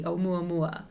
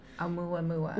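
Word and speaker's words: Oumuamua (ʻOumuamua): That's the pronunciation key, Oumuamua. – Oumuamua.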